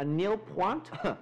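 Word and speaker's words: nul points: Well, Hria, I think that's what they call in Eurovision a nil point. The T is silent, Jessica.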